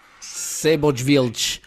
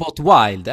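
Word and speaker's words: Seyboth Wild: Seibot Wild, eller? Är det fel? – Nej, du får, ja, det är fel uttal, men du får ju säga det för att det kanske låter bättre för oss.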